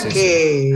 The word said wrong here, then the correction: Cake: Cake. Claro. La banda se llama Cake y la banda estadounidense Cake. Se escribe C-A-K-E.